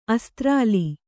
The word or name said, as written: ಅಸ್ತ್ರಾಲಿ